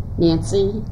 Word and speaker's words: Nancy: Nancy J. Higgins, N-A-N-C-Y, J, H-I-G-G-I-N-S.